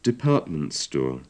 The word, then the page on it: department store: English